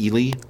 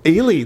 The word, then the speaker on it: Ely: Excuse me, that's pronounced Ely. – Ely, thank you. I didn't know. – Yeah, thank you. Good. – No, she is. Yeah, Ely, Minnesota.